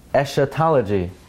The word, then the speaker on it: eschatology: eschatology.